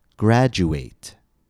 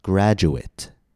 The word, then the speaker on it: graduate: When ate is used in an adjective or a noun, it will be pronounced it. For example, graduate. – The syllable stress is in the same place, but the pronunciation of the letter a changes.